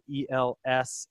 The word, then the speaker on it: Elsasser: For me, everybody, if you want to come follow me, I'm Johnny Elsasser at Instagram. My last name is spelled Elsasser.